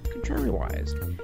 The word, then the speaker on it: contrariwise: Contrary-wiz. Huh? It is spelled C-O-N-T-R-A-R-I-W-I-S-E. Contrary-wiz. Wise. Wise. Contrary-wise.